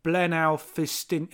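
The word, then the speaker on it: Blaenau Ffestiniog: Again, I will apologise to my Welsh family for that poor pronunciation at this point. – No, Dave, let me try. Blenau Ffestiniog. Oh, okay, I said it how you said it.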